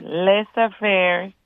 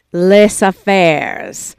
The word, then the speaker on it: Les Affairs: Les Affairs, event planning specialist in catering. – Les Affairs.